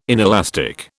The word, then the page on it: inelastic: British English